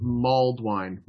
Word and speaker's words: mulled wine: Blinky, it's pronounced mulled wine. Mulled wine, not mold wine.